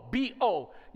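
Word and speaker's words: bo (בוא): The original Hebrew word is bo, B-O. You pronounce it bo like shooting a bow.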